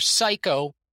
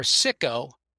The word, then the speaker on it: SIKKOH: By the way, I renamed the convention SIKKOH, which you can pronounce either psycho or sicko, but obviously what it is it's scrambling the letters of Kokish.